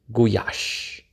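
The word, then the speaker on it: gulyás: Goulash is a German corruption of Gulyas, pronounced Guyash, which means cowboy, and by extension, the stew he cooks over his campfire in Nagy Alföld or Prairie.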